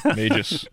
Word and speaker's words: Magus: Is it pronounced Magus or Magus? Yeah, you Magus, know, you, you, nailed it. Everyone else is saying Magus. – Yeah, Magus.